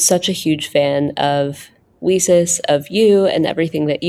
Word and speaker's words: WiCyS: So we often go by our acronym, WiCyS, and we pronounce it WiCyS, like we sisters, because as you know, we're a global cyber sisterhood.